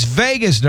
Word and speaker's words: vagus: No, it's vagus nerve. It's not spelled like the fun Vagus. It's V-A-G-U-S.